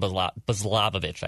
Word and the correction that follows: Balazovic: I can never pronounce this guy's last name. – I think it's Balazovic. – Balazovic.